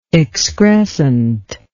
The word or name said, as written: excrescently